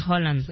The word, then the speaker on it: Haaland: Su pronunciación es Haaland. – Haaland, de hecho, ayer me pegó un tirante también con mi esposa hablando de eso.